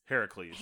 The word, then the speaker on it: Heracles: And what we should note here is Hercules, the way it's spelled and pronounced is the Roman. – Which is funny because they make a big deal about this being specifically Greek. – Because the Greek is Heracles.